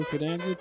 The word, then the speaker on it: Fidanza: Fidanza is F-I-D-A-N-Z-A. If you want to look it up and follow along. – Fidanza.